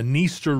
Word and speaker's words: Dniester: And Dniester, I love a river with a silent D. – This has a silent D at the try, right? You might want to pronounce this the Dniester River, but the D is silent.